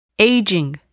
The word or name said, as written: aging